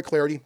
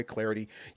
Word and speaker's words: Clardy: You can follow me on Twitter, at Troy Clarity. Last name is spelled C-L-A-R-D-Y, at Troy Clarity.